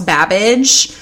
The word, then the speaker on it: Babbage: And Somerville introduced Ada to very famous British inventor by the name of Charles Babbage, which, like, I love because it rhymes with cabbage.